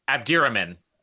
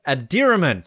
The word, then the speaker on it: Abdirahman: Pronounce it Abdi Abdi Abdirahman. – Abdirahman. So I got it completely wrong.